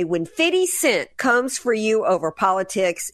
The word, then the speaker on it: 50 Cent: The rapper 50 Cent. Okay, did I say that right? Sesame broccolini. – Fact check true. 50 Cent.